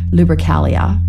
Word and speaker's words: Lupercalia: So from February 13 to 15, the Romans celebrated the feast of Lupercalia.